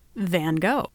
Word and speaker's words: Van Gogh: So I'm going to go ahead and continue using the Americanized pronunciation of Van Gogh. So, onward we, Van Gogh.